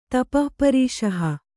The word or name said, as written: ತಪಃಪರೀಷಹ